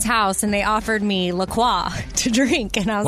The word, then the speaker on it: La Croix: And they even say on their website, it's definitely pronounced La Croix. And if you ever get confused about it, just remember it rhymes with enjoy.